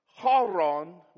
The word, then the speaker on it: Haran: The names, Haran was how you say it, is his son.